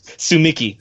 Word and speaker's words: Tsumiki: Is that how you pronounce it? Mikan Tsumi? Tsukumi? Tsukimiki? – Sumiki. Mikan Tsumiki, yeah.